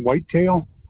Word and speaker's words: Whitetail: Nelson Whitetail.